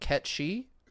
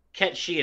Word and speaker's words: Cait Sith: are up in arms, and I believe it's supposed to be what Ketchy. Ketchy. – Yeah, Ketchi is how you actually pronounce it in its original Gaelic, is, is uh, for what I understand.